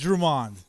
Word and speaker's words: Drummond: And another stud, you got Luke Drummond from the British Columbia Bombers. – It's Drummond. – Drummond. I'm sorry. These Drummond. Canadians have different pronunciations.